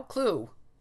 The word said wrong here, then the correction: clough: It's clough. – Clough.